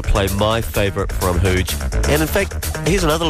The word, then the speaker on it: Hooj: The label I am talking about is Huge Tunes. It's spelled H-O-O-J.